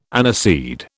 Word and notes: aniseed: British English

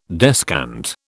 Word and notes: descant: British English

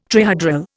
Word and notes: trihedral: Indian

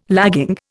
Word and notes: lagging: Indian

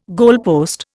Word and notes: goalpost: Indian

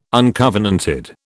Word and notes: uncovenanted: British English

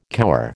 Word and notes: cower: American English

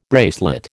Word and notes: bracelet: American English

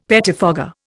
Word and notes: pettifogger: Indian

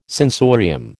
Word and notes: sensorium: American English

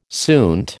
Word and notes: suint: American English

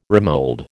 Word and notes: remould: American English

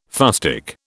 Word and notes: fustic: British English

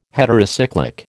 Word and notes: heterocyclic: American English

